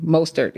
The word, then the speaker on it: Mostert: For showing out, I have Raheem, and I always struggle with pronouncing his name. Raheem Mostert? – Mostert, yes.